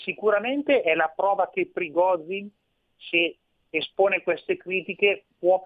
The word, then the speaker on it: Prigozhin: E come la leggi questa, queste dichiarazioni di un, di un personaggio, insomma, di, di, un certo rilievo come Prizogin che ehm, Prigozhin che eh, si, si, pronuncia in, in, questa, in, questa, maniera.